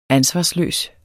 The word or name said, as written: ansvarsløs